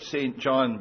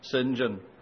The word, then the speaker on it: St. John: His name looks like St. John, but that's how it's pronounced. It's pronounced St. John.